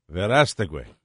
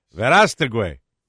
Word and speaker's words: Verástegui: How do you pronounce his last name? V-E-R-A-S-T-E-G-U-I. – Verástegui. Verástegui. Eduardo Verástegui.